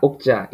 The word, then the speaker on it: Okja: I watched that when it was released, and I noticed watching it like yesterday that apparently, so the pronunciation, pretty much everyone except, well, actually, yeah, the Korean girl, she says, is that like Okja? Okja? Is that the correct spelling? I mean... – Yeah, Okja, Okja, yeah.